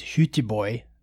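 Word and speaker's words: Göteborg: The first one that I went to, they used to have in Europe, and so my wife and I went to the native pronunciation is Hüteborg, which is Gothenburg, Sweden, and so we went in 2011.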